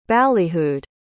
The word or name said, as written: ballyhooed